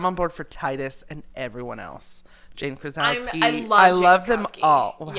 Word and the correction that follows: Krakowski: However you say her name. – Jane Krakowski. – Or Krasowski, like I said. – You're thinking of John Krasinski.